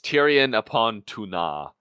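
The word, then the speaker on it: Túna: you put the syllable along the wrong emphasis. it's Tirion upon Tuna.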